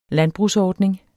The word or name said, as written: landbrugsordning